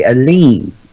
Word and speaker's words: Eliene: Aline, never forget. – Aline? – Yes. – How do you spell that, dude? – E-L-I-E-N-E. – E-L-I-E-N-E. Okay, Aline.